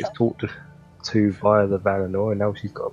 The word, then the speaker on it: Valinor: Was it Valoria? – Valinor. – Valinor. That's how you pronounce it.